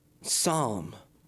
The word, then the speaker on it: Psalm: So let's just play part four, seldom played, part four of A Love Supreme, the one that he titled Psalm, talking about a silent letter. The P is silent in Psalm.